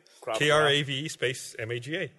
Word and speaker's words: Krav Maga: Krav Maga.